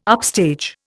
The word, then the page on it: upstage: Indian